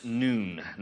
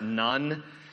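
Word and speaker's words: nun: The letter that marks this section is pronounced noon, not none. That is something different that some of you grew up with who threw rulers across your knuckles. This word is noon, 105.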